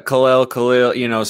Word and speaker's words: Kel'el: and is that the proper pronunciation? – Kel'el, somewhere in that range, it kind of floats back and forth, but you got it.